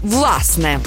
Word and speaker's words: власне: Наголос на першому складі «власне».